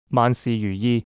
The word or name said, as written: 万事如意